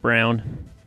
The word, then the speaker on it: Braun: It's pronounced Brown. – I say Chris Braun. I like Braun better. I like Braun better. I refuse to call him Brown because it's B-R-A-U-N.